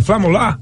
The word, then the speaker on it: flamular: Flemulando a bandeira flemula. – Como eu falei é flemula? Eu falei o quê? – Você falou flemulando. – Como é que fala? É flamular? – Flamular, tremular, balançar.